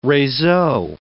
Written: reseau

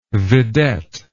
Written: vidette

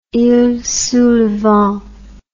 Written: iles sous le vent

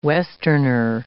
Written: westerner

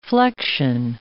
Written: flexion

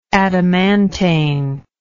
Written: adamantane

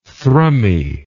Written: thrummy

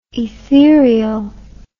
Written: etherial

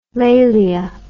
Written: lalia

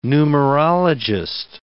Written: numerologist

